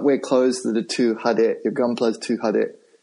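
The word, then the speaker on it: hade (派手): The word I'm thinking of is hade. We would spell it H-A-D-E.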